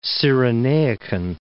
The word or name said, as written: Cyrenaican